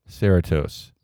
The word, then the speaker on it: Cerritos: This is from Michael Rhodes, Cerritos, California. – Cerritos? – Am I saying that right? – I don't know. – Cerritos. C-E-R-R-I, like Doritos, except for Sarah.